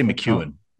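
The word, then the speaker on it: McKeown: How do you pronounce his last name? – I say McEwen. – McEwen? – I say McEwen, yeah.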